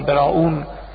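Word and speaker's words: Browne: براون، اگر جای خوندید برام رو ادوار براون اینطور تلفظ میگید، این آقای ادوار براون، اونا سرمشق و الگوی همه تطبعات تاریخی ادبی سیاسی بود در اون زمان.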